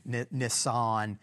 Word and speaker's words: Nisan: It's not spelled exactly like the car, but it's, you know, that's a close enough pronunciation for Texas anyhow. Nisan, the 14th, the 24th day.